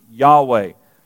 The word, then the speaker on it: Yahweh: They put it, because there are no vowels in it, Lord's name, Bible scholars put an A and an E and pronounce it Yahweh.